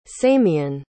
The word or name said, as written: samien